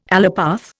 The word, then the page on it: allopath: Indian